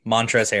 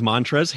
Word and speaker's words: Montrezl: It's the guy with a silent L in his name, Montrezl Harrell. – I had to look up how to say his name because I had no idea looking at it. I was like, is that Montrezl? Yes, it is Montrezl Harrell. That is correct.